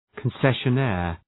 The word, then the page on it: concessionaire: {kən,seʃə’neər}